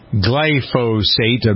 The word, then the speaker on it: glyphosate: He claims that Roundup has as its main ingredient glyphosate. I believe that's how you pronounce it. – Sure.